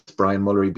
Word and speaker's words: Bryan Mulry: Um, my name is spelled out here, but if you're listening to it, it's Bryan Mulry,